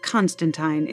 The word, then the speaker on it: Constantine: For the record, it's pronounced Constantine in the comics.